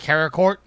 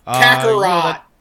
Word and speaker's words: Kakarot: Dragon Ball Project Z is now Dragon Ball Z Karakort. – Kakarot.